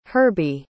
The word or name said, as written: herbie